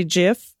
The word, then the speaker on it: GIF: hard G, hard G GIF, hard G GIF.